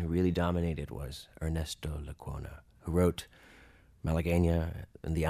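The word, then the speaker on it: Lecuona: Lacuna. Ernesto Lacuna.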